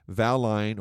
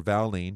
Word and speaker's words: valine: The other two are isoleucine and valine or valine, depending upon how you want to pronounce it.